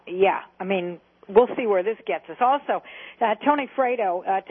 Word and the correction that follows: Fratto: today, or Fratto, actually, is more how he pronounces his name.